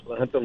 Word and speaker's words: La Junta: La Junta Lamar area. Am I saying that right? – Yeah, La Junta Lamar, southeast, about 60 miles southeast of Pueblo.